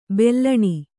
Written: ಬೆಲೂನು

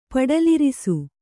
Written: ಪಡಲಿರಿಸು

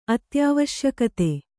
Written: ಅತ್ಯಾವಶ್ಯಕತೆ

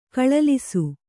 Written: ಕಳಲಿಸು

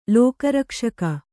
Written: ಲೋಕರಕ್ಷಕ

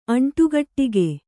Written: ಅಂಟುಗಟ್ಟಿಗೆ

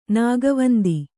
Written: ನಾಗವಂದಿ

